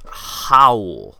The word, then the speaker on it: Raul: His name is actually pronounced Howl.